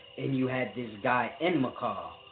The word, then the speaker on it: Enmerkar: Now, when you're dealing with, it's a deep thing when you're dealing with Enmerkar, because you pronounce his name in the Sumerian spelling, Enmerkar, Enmerkar, America.